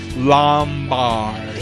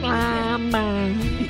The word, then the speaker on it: Lombard: I love that you pronounced it just like the Metro announcer, Lombard. – Next time, Lombard.